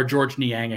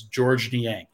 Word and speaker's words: Georges Niang: I just I I compared him to Georges Niang or George Niang, excuse me. I keep calling him Georges because that's how his name is spelled. But I was I was uh, told on a podcast that or on, uh, like on my own podcast that it is George Niang.